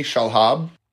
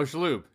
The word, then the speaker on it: Shalhoub: because I picked Tony Shalhoub. – Oh, Shalhoub, yeah.